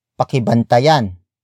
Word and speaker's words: pakibantayan: Word for word it's, pakibantayan, ninyo, ang, aking, bahay. Pakibantayan, ninyo, ang, aking, bahay. Pakibantayan ninyo ang aking bahay.